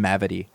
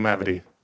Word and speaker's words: Mavity: Can I just say, by the way, I've never heard the name Mavity, and I really like saying it. – It's a good name, Michael Mavity. – It rhymes with gravity.